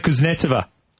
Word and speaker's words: Kuznetsova: I can't pronounce Kuznetsova. – It. – Kuznetsova. – That's it.